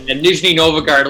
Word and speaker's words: Nizhny Novgorod: We are now pleased to be joined by the head strength and conditioning coach of the, I'm probably going to butcher this, Torpedo Nizhny Novgorod. How's it going? – Good, boys. Good. Yeah, close enough. And Nizhny Novgorod, a little bit of a mouthful, but good to be on here, boys.